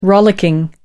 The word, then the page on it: rollicking: American English